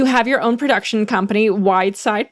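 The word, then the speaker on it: Wydsyd: I had to spell it weird, so everybody throws everybody off, but it's W-Y-D-S-Y-D. Thanks for saying it right. – What's the worst pronunciation you've heard? – Weedy CD, I think.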